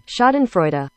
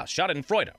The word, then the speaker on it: schadenfreude: Let's get the Google pronunciation. – Schadenfreude. – Ah, schadenfreude. Okay, schadenfreude.